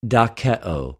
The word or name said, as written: δοκεω